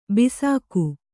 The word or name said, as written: ಬೇಸಿಗೆ